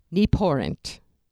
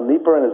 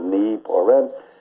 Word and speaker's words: Nieporent: Well, but when it came to pronouncing the name, she'd point to her knee, then she'd pour wine, and then she'd pay the rent by sticking her hand out. So it was knee, pour rent.